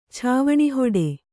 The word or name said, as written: ಛಾವಣಿಹೊಡೆ